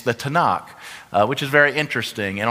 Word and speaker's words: Tanakh: The Tanakh is how it's said.